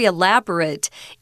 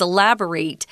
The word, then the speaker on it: elaborate: Oh, uh, the design of that shirt is very elaborate. It, it, it's that uh, short I sound, it, not eight, which is what this is, elaborate um,